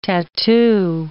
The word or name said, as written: tattoo